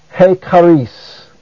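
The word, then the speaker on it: he charis (ἡ χάρις): Also notice that hey is a proclitic. That is, it goes so closely with the word that follows as to have no accent of its own. Therefore, it is pronounced he charis.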